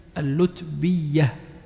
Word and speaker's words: اللتبية: يقول لأنه اللتبية بضم اللام اللو وإسكان التاء اللت والمثنى الفوقية وكسر الباء اللتبي وتشديد الياء اللتبية إذن هكذا تنطق حسب الضبط الموجود.